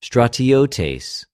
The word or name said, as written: στρατιωτης